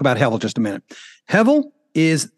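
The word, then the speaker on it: hevel (הבל): It's the word hevel. Now, in the Hebrew, if you translate it over to English, it would be H-E-B-E-L, and you pronounce the B with a V. So hevel.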